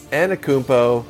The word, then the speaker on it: Antetokounmpo: It's Antetokounmpo.